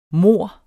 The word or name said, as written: mor